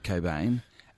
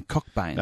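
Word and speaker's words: Cockbain: Kurt Cobain, and not Ian Cockbane. – No, not Cockbane. – It's a silent CNK, which we talked about last week. I don't know too many names where you have 2 silent letters.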